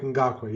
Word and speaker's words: Ngakoue: Is that how you pronounce it? – Yannick Ngakoue, yeah.